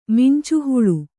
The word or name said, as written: ಮಿಂಚುಹುಳು